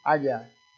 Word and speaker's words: Haggai: H-A-G-G-A-I. Agia.